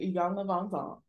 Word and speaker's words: Iyanla Van Zant: That's how they say her name, Iyanla Van Zant.